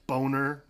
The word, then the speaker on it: Bohner: B-O-H-N-E-R. – Yes. – Boner. – Which is Boner or Bonner, depending on how you pronounce it. Apparently he pronounces it Boner, but he laughs like really weird when Monica says that.